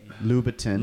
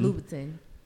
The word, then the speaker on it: Louboutin: Number two, Louboutin? Is that how you say it? – Yes. – Louboutin. – Louboutin.